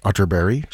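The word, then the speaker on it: Atterberg: Spelled Otterberg, but pronounced Otterberry, just in case you were Googling.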